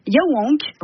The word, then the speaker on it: Yaouank: Et Nora, à Rennes, on va pouvoir danser au festival Yaouank. – Ouais. – C'est ça, se prononce comme ça.